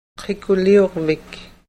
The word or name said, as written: qikuliurvik